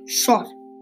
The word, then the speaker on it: chat: Cat means shot. Shot shot. Sounds a little bit like shot S H O T, but shot is spelled C H A T.